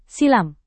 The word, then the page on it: silam: Indonesian